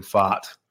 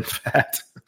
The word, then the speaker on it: Pfaadt: By the way, I did confirm with Chris Crawford. It is Brandon Pfaadt. It's not Brandon Pfaadt, as I wanted to pronounce it a few weeks ago.